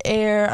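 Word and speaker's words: Aire: Yeah, a TikTok or an Instagram account called like Kylie's TikTok or something posted like, do you think his name is pronounced Air or Airy? And she commented Air.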